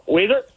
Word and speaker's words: Weezer: It rhymes with sneezer. – Oh, Weezer? – Yeah.